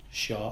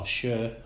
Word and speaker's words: Shaw: Lascelles, Clark and Shaw, or Scher, as he's told us that's how you pronounce his name, so start that from now. So those three are more like old school sort of stoppers, if you like.